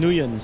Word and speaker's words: Nguyen: I have Tom Nguyen, silent G.